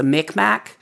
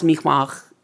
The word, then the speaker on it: Mi'kmaq: But you're looking at a story that was born in Nova Scotia and in the where the Mi'kmaq and the, the most most uh, Americans are going to think of them as the Mi'kmaq, but it's it's pronounced Mi'kmaq,